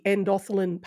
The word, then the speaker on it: endothelin: So let's talk a little bit about the endothelin pathway.